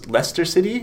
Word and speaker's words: Leicester: Right, but the teams that you hear about, like it was recently all over the news here that I believe it's Leicester City, is how you pronounce it? – Yeah.